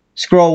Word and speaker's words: Skrull: Skrull with a U, by the way. – Skrull. – It rhymes with hull, like hull of a ship.